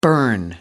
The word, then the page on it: burn out: American English